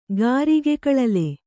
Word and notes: ಗಾರಿಗೆಕಳಲೆ: Kannada

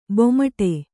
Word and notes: ಬೆಮ್ಮಾಡು: kannada

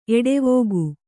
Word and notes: ಎಡೆವುಗು: eḍevugu

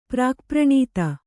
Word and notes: ಪ್ರಾಕ್ಪ್ರಣೀತ: Kannada

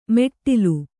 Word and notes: ಮೆಟ್ಟಿಲು: meṭṭilu

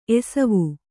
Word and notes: ಎಸವು: Kannada

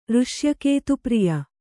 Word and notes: ಋಷ್ಯಕೇತುಪ್ರಿಯ: Kannada

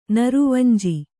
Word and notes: ನೀರವಂಜಿ: nīravanji; Kannada

